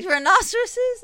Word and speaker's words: rhinoceroses: Rhinoceroses? Is that how you say it? – Yeah.